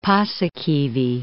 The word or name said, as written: Paasikivi